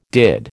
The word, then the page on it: did: American English